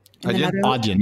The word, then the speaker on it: Adyen: Well, it competes pretty directly with, I don't know how to say it, Adyen. – Adyen, yeah.